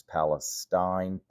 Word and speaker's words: Palestine: It's spelled P-A-L-E-S-T-I-N-E, which I would normally pronounce as Palestine, but apparently it's pronounced locally by people who live there. It's pronounced Palestine.